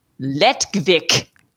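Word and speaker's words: Lettkveik: And it is called Lettkvik. – It's less fun. I think. It's less fun. – It's L-E-T-T, okay. New word, K-V-E-I-K. – So it's the same kvik, but...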